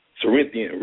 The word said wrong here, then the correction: circadian: rhythms. – Circadian rhythms. There you go.